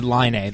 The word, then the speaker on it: Laine: Is it Laine or Laney? I don't know how to say it. – He said Laine. That's the Laine way he wants it.